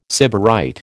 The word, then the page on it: sybarite: American English